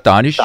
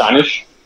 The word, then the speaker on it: Danish: Dit navn staves Danish, men udtales... – Ja, det gør det. Danish. Danish.